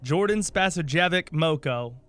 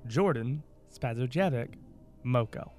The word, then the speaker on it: Spasojevic-Moko: Jordan Spasojevic-Moko. – Mm-hmm. – J-O-R-D-A-N. – Got that. – S-P-A-S-O-J-E-V-I-C-M-O-K-O. Jordan Spasojevic-Moko.